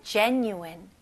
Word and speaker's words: genuine: genuine. 네, 스펠링은 G지만 J 발음으로 해주셔야 됩니다. genuine. genuine. 오케이? 여기 new n-u-i 부분에는 new n-y-o-o 느낌으로 한번 해보세요.